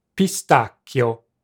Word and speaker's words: pistacchio: In Italian, it's just three syllables. – Pistacchio.